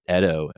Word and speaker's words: Edo: It takes place in the Edo period, which is, I always thought it was pronounced Edo, but they pronounce it Edo in the show.